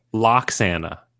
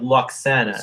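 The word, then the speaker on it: Roxana: How do you say her name? – Loxana. Yeah. – Like bagel and lox. – Yeah. – Because it's spelled Lowox, right? It's like LW. – Yeah, it's just kind of like Loxana. – So it's a silent W.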